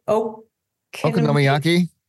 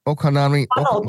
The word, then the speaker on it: okonomiyaki: Okonomiyaki. – He did that, but it was an okonomiyaki. – Okonomiyaki.